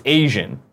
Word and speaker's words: AZN: AZN should be pronounced Asian, but I pronounce it, I do fake AZN because I'm just like, is he using it like a slur?